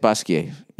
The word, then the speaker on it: Basquiat: All right, Basquiat / Basquiat, is that how you say it? Basquiat, yeah. I blend that with my French studies. You don't pronounce the T unless there's an E after it.